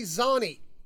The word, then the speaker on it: Zani: If you live in School District 15 in Utah, if you see the name Tony Zani, looks like it's spelled like Zany. Z-A-N-I.